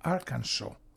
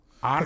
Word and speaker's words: Arkansas: Δεν έχω πάει, αλλά οι Αμερικάνοι ξέρεις με την προφορά το λένε Arkansas. – Arkansas, το τρώνε το αλφα σίγμα.